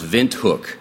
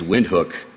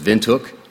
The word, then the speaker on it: Windhoek: This is the capital here, and it's pronounced Windhoek. I know it looks like it's Windhoek, but it's German, and the W's are V's. So it's Windhoek.